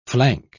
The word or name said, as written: flank